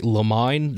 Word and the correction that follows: limine: Does that sound right? – Motion in limine. – Limine, yeah.